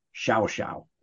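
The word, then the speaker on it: Llao Llao: There's an incredibly upscale resort called Shao Shao. It's spelled LLAO-LLAO, but down there in their Castellano Spanish, it's pronounced Shao Shao.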